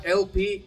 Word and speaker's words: LP: Se escribe ELP, se llama LP, es una gran ah.